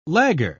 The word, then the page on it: lagger: British English